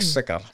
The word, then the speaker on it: Siccar: S-I-C-C-A-R. Oh. Mark Sicker.